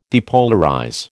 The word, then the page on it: depolarize: American English